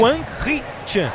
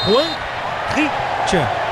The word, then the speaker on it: Hwang Hee-chan: Como, viu, como é que fala o nome do cara aí? – Hwang Hee-chan. Hwang Hee-chan.